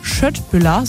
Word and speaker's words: Köttbullar: Eine Sache, die bei IKEA ganz sicher nicht fehlen darf, eine schöne Portion Köttbullar nach dem Shoppen oder auch Schöttbullar, so wird es ja richtig ausgesprochen.